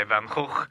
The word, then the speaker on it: Van Gogh: The Sunflowers by Van Gogh. – Is that how you say his name? – That's how you say his name, yes.